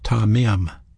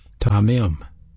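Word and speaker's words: tamim (תמים): That word sincerity is a Hebrew word pronounced tamim, tamim, and it means wholeness or complete, undivided attention, integrity, making His will priority number one in your life.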